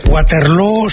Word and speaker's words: Waterloo: Waterloo se pronuncia igual que Feijóo.